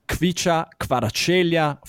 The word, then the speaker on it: Kvaratskhelia: The liberal pick is Kvicha Kvaratskhelia from Napoli and Georgia.